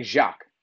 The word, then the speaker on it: Jacques: keep saying Jack. – No, I'm saying Jacques. – That now you said it right.